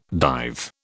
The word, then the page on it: dive: British English